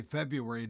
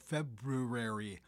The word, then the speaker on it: February: And we always say February, don't we? Instead of the proper pronunciation of February. We always seem to lose that first R.